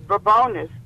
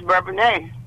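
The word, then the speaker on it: Bourbonnais: Well, if you're from the city, it's Bourbonis, but if you move into the little village, it's Bourbonia.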